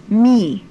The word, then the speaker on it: me: Me, a long E.